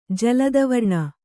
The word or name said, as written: ಜಲದವರ್ಣ